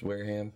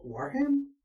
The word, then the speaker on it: Wareham: But see, it's W-A-R-E. It's a town in Massachusetts. – W-A-R-E? – W-A-R-E-H-A-M. Wareham. – Wareham? – Would it be?